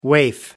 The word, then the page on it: waif: American English